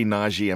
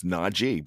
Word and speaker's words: Najee: So if you say Najee, I'm going to have to correct you. It's Najee, because he's serious about that. That's one thing that he wants. He wants you to pronounce his name right. Or I don't want them spelling it B-R-I-A-N.